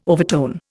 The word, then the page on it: overtone: Indian